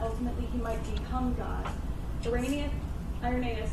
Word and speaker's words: Irenaeus: So, Irenaeus, am I saying that right? – Irenaeus. – Irenaeus. – Yeah.